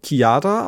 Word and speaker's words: Chiara: Und dann haben wir noch von, das mit der direkt schicken, weil es sehr schön war, von, spricht man, spricht man eigentlich eine C-H-I-A-R-A kiara aus? Weil es gibt ja Leute, die sagen Chiara und das finde ich ganz schlimm. Nee, das ist, glaube ich, wirklich extrem falsch, das so zu betonen.